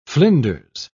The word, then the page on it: flinders: British English